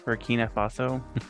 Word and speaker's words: Burkina Faso: Burkina Faso. I put the wrong emphasis. Sorry. – You put the emphasis on the wrong syllable.